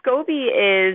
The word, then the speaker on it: SCOBY: And I noticed that you say in the description in the book it's fermented by a SCOBY. Am I pronouncing that right? – Yeah. So SCOBY is, it's S-C-O-B-Y, and it stands for Symbiotic Colony of Bacteria and Yeast.